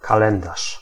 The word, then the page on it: calendrier: [œ̃ ka.lɑ̃.dʁi.je]; France